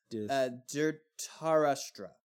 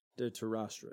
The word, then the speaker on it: Dhritarashtra: I can't pronounce it, the D one you just said. – Dhritarashtra. – Dhritarashtra, yeah. He was the main...